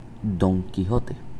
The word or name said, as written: Don Quijote